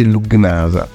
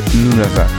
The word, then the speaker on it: Lugnasa: Allora si scrive Dancing at Lugnasa, però non si legge Lugnasa, si legge Lunasa, è irlandese, ed è due coglioni incredibili.